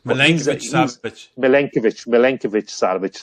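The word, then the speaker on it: Milinković-Savić: Even if you look at the quality of players on the bench, the likes of Filip Kostic and Milinkovic-Savic, whatever way you pronounce that Lazio player. Sorry, I'm murdering it. – Milinkovic-Savic. – Thanks.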